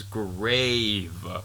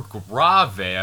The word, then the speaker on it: grave: I have another tempo for you, and it is grave, or grave, I've heard it pronounced both ways.